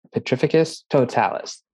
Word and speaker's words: Petrificus Totalis: Wingardium Leviosa, Expelliarmus, Petrificus Totalis.